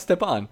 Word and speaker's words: Stepan: Why can I still not pronounce Stepan? I can't do it. – It's like, you know, you step on a bug. – Yeah, Stepan. – Derek Stepan bugs.